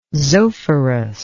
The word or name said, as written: zophorus